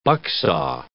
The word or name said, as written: bucksaw